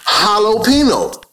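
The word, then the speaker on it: jalapeño: Now we look in English for the Spanish J, and J always is pronounced in English like a H, but more emphatic. Jalapeno. Jalapeno? Jalapeno? Jalapeno? Okay? Are you following me? Now, this Spanish, J-O-T-A, is really from Latin or what we call Greek, because that's really the origins, iota.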